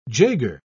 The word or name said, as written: jaeger